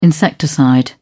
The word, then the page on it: insecticide: British English